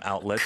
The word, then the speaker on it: outlet: 그 발음을 할 때 outlet 이렇게 하나요? – outlet. – Outlet. – Outlet. – 아, 중간에 한 번 딱 끊어지게 되는군요.